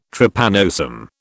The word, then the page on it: Trypanosome: British English